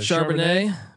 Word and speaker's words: Charbonnet: Zach Charbonnet, how do you pronounce it? – Charbonnet, Michigan transfer. – Nice little glass French. Of Charbonnet. – It's French, yeah.